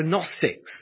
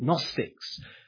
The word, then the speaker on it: Gnostics: Well, the name you can impress your friends with here were Gnostics, spelt with a G, but Gnostics, who had some very highfalutin ideas about the sort of realm of the heavenlies.